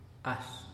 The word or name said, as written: axh